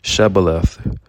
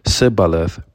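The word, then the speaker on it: shibboleth: He faced the three and said, I want each of you to say one word, Shebaleth, Shebaleth. The three turned white with fear. One by one, they carefully pronounced Sibaleth. Jephthah shook his head in amazement. They really couldn't hear the difference.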